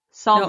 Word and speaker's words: salmon: Listen, like it's spelled. S-A-L. Sal. – Sal. – Mon. – Salmon. No, it's salmon. Drop okay. The L. The L is silent.